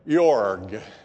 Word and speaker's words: Iorg: And it's written by Dr. Jeff Yorg. It's spelled I-O-R-G.